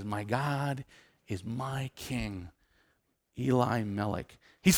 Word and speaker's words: Elimelech: And a man who I'm calling Eli Melek, because if you were to say, my God is my king, you would say Eli Melek. Now we would read that and go, that's Elimelech. That's how you'd pronounce it.